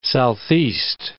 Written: southeast

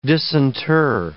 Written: disinter

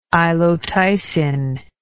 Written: ilotycin

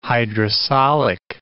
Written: hydrosolic

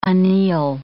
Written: anneal

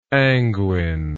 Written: anguine

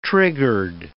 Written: triggered

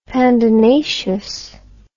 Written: pandanaceous

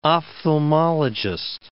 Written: ophthalmologist

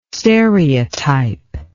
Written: stereotype

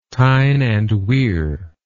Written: Tyne and Wear

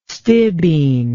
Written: stibine

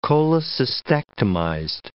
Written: cholecystectomized

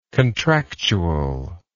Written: contractually

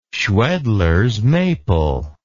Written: schwedlers maple

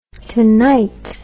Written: tonite

